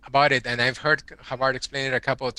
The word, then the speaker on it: Havard: It says, uh, in his book, Virtuous Leadership, Alexander, how do you pronounce his last name? Havard? Havard.